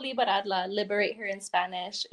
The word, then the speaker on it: liberarla: I speak Spanglish, so like I always switch back and forth, but it's spelled L-I-B-E-R-A-R-L-A. Liberadla.